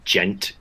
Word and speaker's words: djent: And I guess spurred by the djent movement in metal, and that's spelled D-J-E-N-T. And it is defined as onomatopoeia for the sound.